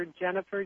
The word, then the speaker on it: Jenifer: So I'm everywhere on Facebook under Jennifer, J-E-N-I-F-E-R, Madson, M-A-D-S-O-N.